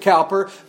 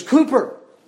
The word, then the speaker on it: Cowper: Now, I pronounce it Cowper, but you know, in England, I visited London one time, and they told me, you American Yankees pronounce his name wrong. They said his name is not Cowper. His name is Cooper.